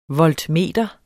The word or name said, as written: voltmeter